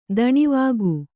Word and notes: ದಾನವಿಕ: dānavika

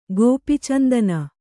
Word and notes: ಗೋಪೀಚಂದನ: Kannada

